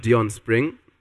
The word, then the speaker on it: Dion Spring: I am Dion Spring. If you can spell it, I am D-I-O-N-S-P-R-I-N-G, like the summer.